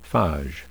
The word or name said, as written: phage